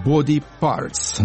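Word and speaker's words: BDY_PRTS: Следующая группа называется «Body Parts», но пишется не так, как надо «Body Parts», а «BDY», нижнее подчеркивание, «PRTS».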